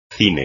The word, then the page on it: cine: espagnol